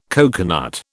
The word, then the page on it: coconut: British English